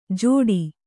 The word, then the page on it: ಜೋಡಿ: Kannada; jōḍi